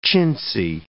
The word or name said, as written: chintzy